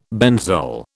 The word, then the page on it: benzol: British English